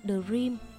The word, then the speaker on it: The Dream: Bố sẽ về chở chúng tôi lên thủ đô bằng chiếc xe rem cũ. Thậm chí khi ấy, tôi chẳng biết tên của nó đúng ra phải đọc là The Dream và ý nghĩa của nó là giấc mơ.